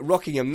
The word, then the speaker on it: Rockingham: Is that how they say it? Rockingham, that is north of Corby? – Correct.